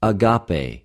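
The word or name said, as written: ἀγαπη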